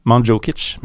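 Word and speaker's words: Mandžukić: Du var på vei til å få en ganske fin uttalelse der. – Ja. Manzukic. Manzukic. Skal dere ha fasiten, eller? Ja, gjerne. – Manzukic. – Manzukic. Manzukic. Ja.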